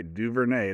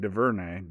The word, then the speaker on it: DuVernay: Gotta say it right, DuVernay. It ain't DuVernay, DuVernay.